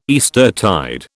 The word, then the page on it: Eastertide: British English